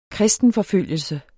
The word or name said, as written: kristen